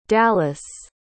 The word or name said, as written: dallis